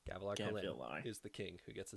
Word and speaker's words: Kholin: Kholin.